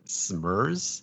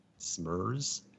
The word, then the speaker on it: Smrz: The trailer I watched was for a film called 24 Hours to Live, directed by Brian Smurz, S-M-R-Z, Smurz.